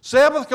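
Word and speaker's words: sabbat (שבת): Sabbath comes from a Hebrew word pronounced sabbat, and it means this: stop.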